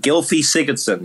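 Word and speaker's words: Gylfi Sigurðsson: Gylfi Sigurdsson.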